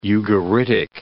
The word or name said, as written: Ugaritic